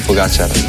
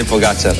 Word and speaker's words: Pogačar: Nos dijeron que están pronunciando el nombre de Pogačar de mil maneras distintas, así que os voy a dejar cómo se dice, porque aquí dice, Juanma dice que es aguda, mientras que otros es drújula, así que aquí tenemos cómo se dice de verdad.